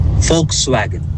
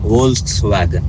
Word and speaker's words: Volkswagen: Right way to say is... – Hanji? – Volkswagen. – Oh, like like a Volkswagen. – And wrong way to say is Volkswagen.